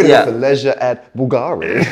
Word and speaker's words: Bulgari: I would say Bulgari, but Mitch pronounced it with a certain flair. – Go on. – Bulgari. – Yeah, it's more Bulgari, yeah.